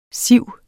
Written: siv

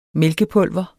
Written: mælkepulver

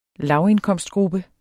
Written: lavindkomstgruppe

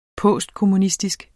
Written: postkommunistisk

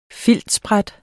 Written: filtsbræt

